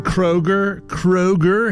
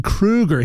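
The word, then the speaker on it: Kroeger: You know, if you've been calling Chad Kroger his entire career, you've been saying it wrong. It's actually Chad Kruger.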